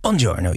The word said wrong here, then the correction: Buongiorno: Bijna. Buongiorno. Je buongiorno. Moet de rollende R.